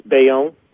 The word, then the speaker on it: Bayonne: You're in Bayonne, is that how you say it? – Yes, Bayonne, yep.